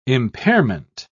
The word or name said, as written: impairment